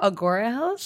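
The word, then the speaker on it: Agoura Hills: We went on a fun little daytime date to Mercado Sagrado in Angora Hills at the Paramount Ranch. – Angora Hills? – Yeah, is that how you say it? – Agora Hills? – Sure. Yeah. No N.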